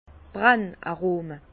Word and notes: d' Brenn-Arome: Bas Rhin; Herrlisheim

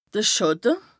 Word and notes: de Schàtte: Bas-Rhin; Strasbourg

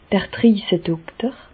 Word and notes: d'r Driesedokter: Bas-Rhin; Reichshoffen

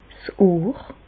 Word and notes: 's Ohr: Bas-Rhin; Reichshoffen

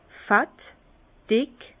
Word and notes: fett, dick: Bas-Rhin; Herrlisheim

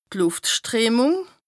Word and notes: d' Lùftstreemùng: Bas-Rhin; Strasbourg

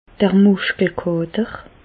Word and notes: d' Mùschkelkàter: Alsatian; Bas-Rhin; Reichshoffen

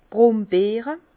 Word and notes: d' Brombeere: Bas Rhin; Herrlisheim

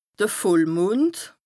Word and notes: de Vollmond: Bas-Rhin; Strasbourg

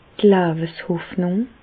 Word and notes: d' Leweshoffnùng: Bas-Rhin; Reichshoffen

